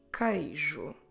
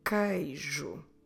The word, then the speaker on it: queijo: Queijo. Queijo. So we start with a k, we don't read the u, and the following vowel is the vowel E, or in Portuguese, E. Queijo. Como se escreve queijo? Q-U-E-I-J-O.